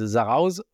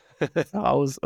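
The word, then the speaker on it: Zarautz: Is it Zarauz? Sarho? – Yeah, you're not supposed to pronounce the T, so it's Zarauz. – Zarauz, okay.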